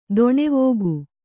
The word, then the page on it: ದಾನವಿಕ: kannada